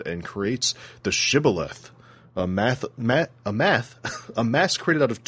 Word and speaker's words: Shibboleth: Which, ironically, as soon as he introduced the term Shibboleth, Zelenin goes, "Shibboleth, huh, that's an interesting term. I've never heard of it." And Mastaba just goes, "Oh, you pronounce that well."